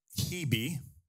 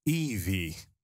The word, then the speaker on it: Hebe: have on the top right-hand corner, Hebe, which is actually pronounced Evie. Evie. That's the original pronunciation, but it got morphed into Hebe.